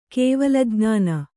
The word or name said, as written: ಕೇವಲಜ್ಞಾನ